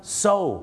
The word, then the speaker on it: sew: so ถูกต้องแล้วครับ sew ออกเสียงว่า so ครับ